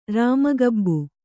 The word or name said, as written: ರಾಮಗಬ್ಬು